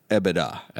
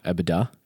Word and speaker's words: EBITDA: Law alum corrected me on the pronunciation. And I think it's EBITDA. – EBITDA.